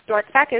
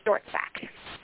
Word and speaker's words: Dortzbach: My name on Facebook is Victoria Christine Dortzack. My last name is spelled Dortzack, is D-O-R-T-Z-B-A-C-H. – Mm-hmm. – That's Dortzack.